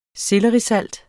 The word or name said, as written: SELLERISALT